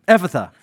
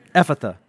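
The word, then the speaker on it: Ephphatha: Ephatha. That's how you say it. Ephatha. It's Aramaic. That is, be opened. I tried to practice it too, but it's gone. Ephatha.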